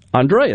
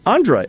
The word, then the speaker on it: Andre: She is Andre, excuse me. I'm just looking at the spelling. Apologize. Did I pronounce it correct? – No, that was correct. Okay. It's Andre. – Andre, okay.